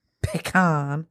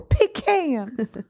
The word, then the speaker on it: pecan: Is it pronounced pecan or pecan? – Pecan, without a doubt. – Pecan. – Pecan pie? – Pecan pie. – I don't know if you're in the South, it's probably pecan pie.